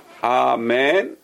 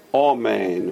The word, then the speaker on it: Amen: One is Amen, which is Sephardi, and one is Omen, which is Ashkenaz.